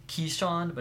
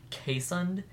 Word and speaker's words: Keeshond: Speaking of which, the dog I want is called, it's spelled, K-E-E-S-H-O-N-D, which is, sounds like Keyshond, but it's actually pronounced Kaysund, and they're Ooh.